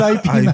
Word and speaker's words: Ip Man: How do you pronounce it? Yip Man or no, Ip Man? – Yeah, Ip Man.